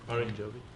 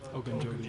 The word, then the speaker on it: Ogunjobi: Ogunjobi. – Ogunjobi.